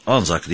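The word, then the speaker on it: ANZAC: Anzak, Australian and New Zealand Army Corps, Anzak diye okunuyor.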